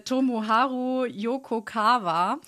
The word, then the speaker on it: Tomoharu Yokokawa: äh, Tomoharu Yokokawa.